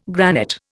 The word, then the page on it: granite: Indian